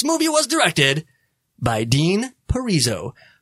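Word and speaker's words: Parisot: I'm so glad I looked up how to say his name. And I don't know why I did, because at first when I was going to say it, I'm like, it's directed by Dean Parizot. That's how it looks like it's spelled.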